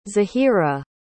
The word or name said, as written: zahirah